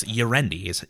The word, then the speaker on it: Ierendi: We actually found out that's it's Ierendi is the canon from the mouth of God pronunciation.